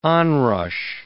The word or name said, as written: onrush